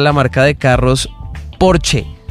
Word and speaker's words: Porsche: Y él dice, no es Porsche, es Porsche. – Es Porsche. – Porsche. Sí, es correcto. Se pronuncia Porsche. Son dos sílabas.